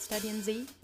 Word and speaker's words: Study in Z: This is Study in Z. Is that how you say it? Study in Z? – That'll do for me.